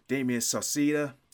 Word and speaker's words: Damien Sauceda: Um, I got Damien Sauceda.